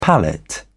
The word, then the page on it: pallet: British English